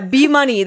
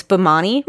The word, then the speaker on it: Bimani: Oh yeah, B Money. That's what I called it because I looked up the like pronunciation on Google, and it sounded like she was saying B Money, but it's Bimani.